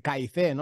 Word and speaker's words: KYC: Esta semana ha salido el tema de Uniswap y el KIC, ¿no? Que se dice así más coloquialmente, el KYC, que es el conoce a tu cliente.